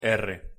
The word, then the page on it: r: Spanish